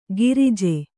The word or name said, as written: ಗಿರಿಜೆ